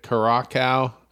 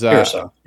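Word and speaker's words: Curaçao: Curacao, which is... – Curacao, yeah.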